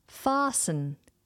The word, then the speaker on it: fasten: Okay, next on our list is a verb, and it's the verb fasten. Fasten. Now, this is spelt F-A-S-T-E-N. But we don't pronounce that T.